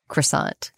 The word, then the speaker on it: croissant: I'm going to say croissant because that's just the accepted Americanized pronunciation.